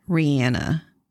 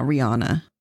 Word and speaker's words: Rihanna: I'm trying to say it by how she actually pronounces it. Rihanna. It's not Rihanna.